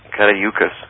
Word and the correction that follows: Caduceus: How did you pronounce Caduceus. – It? Caduceus, that's correct.